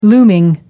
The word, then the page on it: looming: American English